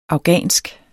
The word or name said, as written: afghansk